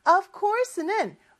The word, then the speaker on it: of course: 자, 그랬더니 of course 라고 대답을 했는데 of course는 물론이죠 라는 말이에요. Sure 하고 같은 의미의 표현이라고 보시면 돼요. 그런데 OF죠. 근데 저 of course라고 하지 않고 F를 V 발음으로 해주셔야 돼요.